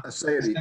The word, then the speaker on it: aseity: Yeah, aseity, A-S-E-I-T-Y.